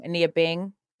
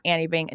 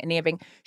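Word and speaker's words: Anine Bing: Ania Bing. It's not Annie Bing. Ania Bing.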